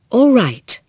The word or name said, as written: alright